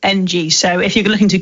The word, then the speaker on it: Ng: Yeah, Ng. Okay. – That's how you say it, I think. – Right, okay. I can never get the names right. – Well, there is no vowels in his surname.